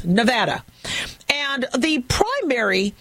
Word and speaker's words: Nevada: They even mispronounce the state I live in. I live in Nevada, and people call it Nevada. You know, like they're trying to make a more bougie sounding. It's Nevada, right?